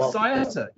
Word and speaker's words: Saietta: I can never pronounce this correctly, but it's spelled S-A-I-E-T-T-A, and the ticket is S-E-D. Just you got over it right. It's Saieta. You got it absolutely right.